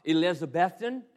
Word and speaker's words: Elizabethton: And if you're heading west and you're going to go to Johnson City, Tennessee, you're going to get around Jonesboro or Elizabethton, and that's how it's pronounced.